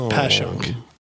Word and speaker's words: Passyunk: I mean, the street is named after being past Shunk Street. – Oh. – So it's Pashunk.